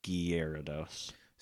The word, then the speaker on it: Gyarados: I think Chris ended up looking it up, and it was Gyarados.